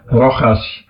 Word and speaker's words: Rojas: We gaan naar Rojas, ja, dat is een Spanjaard, dus dat, daar staat een J, maar dat spreek je uit als een G, Rojas.